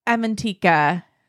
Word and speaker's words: Avantika: Avantika? – Avantika.